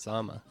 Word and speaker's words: Dzama: Marcel Dzama, how are you? – Very good. It's actually pronounced Zama.